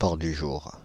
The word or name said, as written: paon du jour